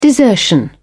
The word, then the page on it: desertion: British English